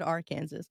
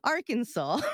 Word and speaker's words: Arkansas: I almost said Arkansas. Arkansas.